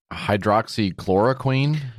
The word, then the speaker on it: hydroxychloroquine: So this guy's trying to do a documentary about hydroxychloroquine. Is that how you pronounce that medicine? – Yeah.